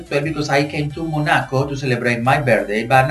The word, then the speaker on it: Monaco: Monaco, that's the right pronunciation? – Exactly, Jose, you're right, Monaco.